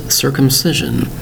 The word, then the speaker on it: circumcision: Today's word is circumcision. Circumcision. Notice the four syllables for the word circumcision. Spelled C-I-R-C-U-M-C-I-S-I-O-N.